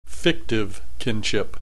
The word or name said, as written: fictive kinship